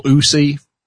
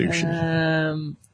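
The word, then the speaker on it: Ushi (ウシ): Old MacDonald had an anime, E-I-E-I-O, and in that anime he had a Ushi. E-I-E-I-O with a... – Ushi? – Ushi. Or Usi. I believe it's pronounced Ushi.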